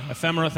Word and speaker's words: ephemera: Ephemera? How do I say it? – Oh, ephemera. – Ephemera, thank you, yeah.